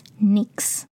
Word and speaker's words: Knix: La marca que más recomiendo se llama NYX y se escribe con K al principio, K-N-I-X, NYX.